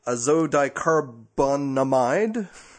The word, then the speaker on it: azodicarbonamide: Long story short, if you want to go read up the little Wikipedia article on azodicarbonamide. – Azocarbone? So it's something you order at a Mexican restaurant. – I had to write out the syllabization, how to pronounce it. – Azodicarbonamide. – There you go.